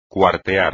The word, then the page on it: cuartear: espagnol